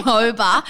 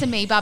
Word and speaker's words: amoeba: I mean, we did get about, I would say, close to 50 DMs about the fact that we may have pronounced amoeba, moba.